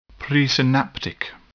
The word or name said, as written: presynaptic